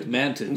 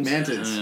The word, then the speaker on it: mantids: He wasn't fucking the mantar, or how do you say, mantars? Or mantars? Centaurs? – Manted. – Mantids. – Mantids.